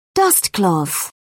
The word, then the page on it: dustcloth: British English